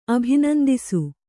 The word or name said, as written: ಅಭಿನಂದಿಸು